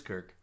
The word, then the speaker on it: Kirk: No, he called him Kurt. His name is Kirk.